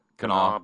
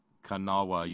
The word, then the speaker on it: Kanawha: Kanawha. – How do you say that? – Kanawha Boulevard. – Kanawha, yeah, Kanawha.